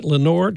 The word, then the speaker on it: Lenoir: Lenoir, spell Lenoir, French pronunciation, but he always called it Lenoir.